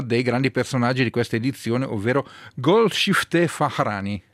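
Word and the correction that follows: Farahani: Però, Farani. – Farani, scusa.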